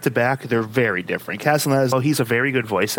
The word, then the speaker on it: Castellaneta: Dan Castellaneta. Did I pronounce that right? – Castellaneta, I believe, is understand.